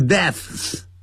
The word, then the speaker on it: deaths: 然后本来是 which causes, which 是关系代词代替前面 war is still going on, 我们可以把 which 去掉，后头动词就变成了现在分词 which causes C-A-U-S-E 加个 S, which 去掉 cause 就变成 causes 就变成 causing, 造成引起 many deaths, 本来是 deaths, 但这个 deaths 难发这样的，是 death 啊，是无声的 death, 然后发一个 s, 所以呢就这样 deaths 这样发音就可以了。deaths 不要念成 deaths, 那不是有声的，是 deaths 就直接念成 deaths every day, 每天就造成许多人的死亡。which means, 一样的，我们可以把 which 去掉，动词 means 就变成 meaning, 一样 meaning, 意思就是说Many families are broken, 许多家庭破碎了 every now and then，不时的，久不久就来这么一次，许多家庭就破碎了啊，这样子，哇，好可怜这样。